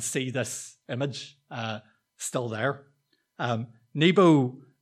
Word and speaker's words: Nebo: And Nebo, sometimes pronounced as Nabu, was Marduk's son. He was the god of writing and wisdom. And this is actually a statue of Nebo that has survived to today from the 8th century BC.